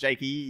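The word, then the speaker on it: Jakeeeeeeeeee: J-A-K-E and then 9 more E's. – We'll read it properly. Jakey. – You can't, that's clearly pronounced Jakey. You can't just, yeah, Jake, normal, 9 E's.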